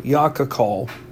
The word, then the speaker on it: yakhchal: That's spelled Y-A-K-H-C-H-A-L, Yakakal.